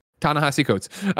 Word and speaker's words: Ta-Nehisi: Ta-Nehisi Coates.